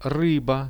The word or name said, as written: ryba